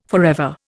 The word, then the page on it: forever: Indian